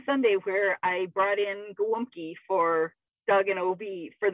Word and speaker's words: gołąbki: You know, those galopkis. And I'm like, what the heck is a galopki? Well, in Polish, the food is pronounced gołąbki, but it is spelled galopki.